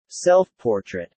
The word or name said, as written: self-portrait